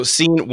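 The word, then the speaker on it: Cine: How do you guys say his last name? – Seen, yeah. – Seen. So he's out of Georgia. Again, we're just pillaging the Georgia defense. So Seen, one of the things that PFF notes about him was that he was really good at reading routes and anticipating breaks.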